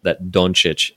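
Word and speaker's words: Dončić: By the way, I hear from Eastern Europeans that Doncic is the proper pronunciation.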